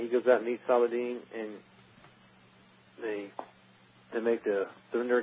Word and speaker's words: Saladin: And I said Saladin.